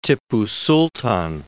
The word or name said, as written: Tippu Sultan